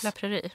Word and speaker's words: La Prairie: Jag har ett från La Praire, eller hur det uttalas. – La Prairie. Ja, La mm. Prairie.